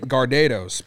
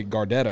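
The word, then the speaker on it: Gardetto's: I pronounce it Gardados, but some people say Gardettos.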